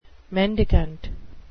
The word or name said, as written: mendicant